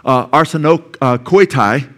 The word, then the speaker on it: arsenokoitai: Now, what's interesting with regard to arsakonotai, you see it up there, arsakonotai, or I'm sorry, arsenokoitai, okay? Arsenokoitai, get to pronounce that right.